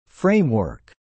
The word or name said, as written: framework